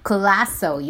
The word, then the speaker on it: Colaco: And Classo, you know, my people just find my name too interesting or maybe too complicated, because my last name is written as C-O-L-A-C-O, but it is pronounced as Classo.